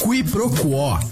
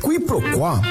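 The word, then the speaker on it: quiproquó: Qui pro quo. – Q-U-I-P-R-O-Q-U-O com acento agudo. – Qui pro quo, também informalmente pronunciada como qui pro quo, é a ação de confundir uma coisa com outra, ou o desentendimento decorrente dessa confusão.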